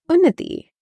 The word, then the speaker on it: Unnati: My name is Unnati Patel, but first, let's address the elephant in the room. How do you really pronounce my name? It is like unity, but with an ooh. So Unnati, okay?